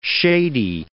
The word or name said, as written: shady